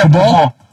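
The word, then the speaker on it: Kubo: Bueno, fíjate que me dijeron que se pronuncia Cubo.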